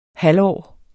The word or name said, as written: halvårs